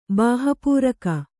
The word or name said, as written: ಬಾಹಪೂರಕ